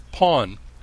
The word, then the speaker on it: porn: It's Thai porn, a restaurant which uses the word porn, which means blessing in Thai, but is pronounced pawn, says its owner, a woman named Pornpan.